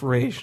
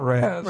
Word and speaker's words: Ra's: and it is pronounced Ra's, not Raz.